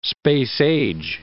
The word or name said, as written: space-age